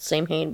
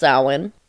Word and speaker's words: Samhain: Its origin comes from an ancient Celtic pagan tradition called Samhain, but it is spelled Samhain, or a lot of people pronounce it Samhain, but it's pronounced Samhain.